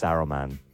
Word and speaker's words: Saruman: I said Solomon. It's Saruman. I said it wrong.